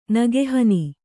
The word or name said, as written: ನೆಗೆಹೆಣ್ಣು